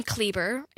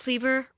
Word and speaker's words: Kleber: My last name, Kleber, it rhymes with Justin Bieber. That's just what I tell people. Like, what's your name, Paige? Paige Kleber, Rumpus Justin Bieber, if you have a hard time remembering that.